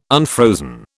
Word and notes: unfrozen: British English

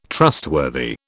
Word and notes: trustworthy: British English

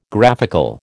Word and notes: graphical: American English

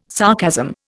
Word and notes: sarcasm: Indian

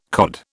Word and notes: COD: British English

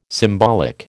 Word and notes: symbolic: American English

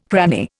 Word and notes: cranny: Indian

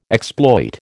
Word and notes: exploit: American English

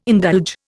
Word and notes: indulge: Indian

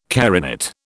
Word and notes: carinate: British English

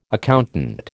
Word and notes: accountant: American English

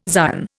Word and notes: Zion: Indian

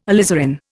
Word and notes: alizarin: Indian

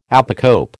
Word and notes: apocope: American English

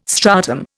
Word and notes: stratum: Indian

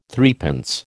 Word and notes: threepence: American English

